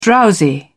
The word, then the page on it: drowsy: British English